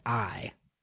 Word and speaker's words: Ai: And it is the city of Ai. Now, again, in the Bible, when we read that, it's spelt AI, and it's not artificial intelligence, but it's pronounced Ai.